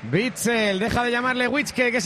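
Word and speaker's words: Witsel: Es Witzel.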